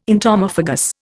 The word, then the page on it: entomophagous: Indian